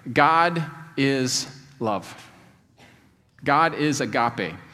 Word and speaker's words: agape: I know, I know it's spelled, it looks like it's spelled agape, but they pronounced it agape, so I'm just going to go with that.